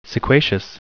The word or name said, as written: sequacious